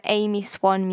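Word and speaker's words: Aimee Swann: If you ever do want to get involved in any of my polls, then do head to my Instagram, Amy Swan Music. That's AIMEESWANN and then music. It's all one word.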